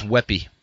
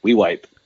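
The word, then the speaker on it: Weippe: I always thought it was Weppy. – Weipe. – Weipe. – That was the big joke, and you know, growing up in Weipe, and then went to high school in Orfino, and all the Orfino guys.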